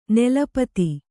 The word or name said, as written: ನೆಲಪತಿ